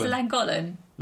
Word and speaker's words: Llangollen: Is that not how you say it? – Llangollen. – It's Llangollen. – Llangollen. – Llangollen. Yeah. – There's no D on the end. Llangollen.